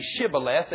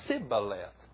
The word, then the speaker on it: Shibboleth: They could not say Shibboleth. They could only say Sibboleth. They couldn't pronounce it.